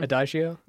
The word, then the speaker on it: Adagio: A-D-I-G-O for strings is a song from... – Adagio? – Yeah, thank you.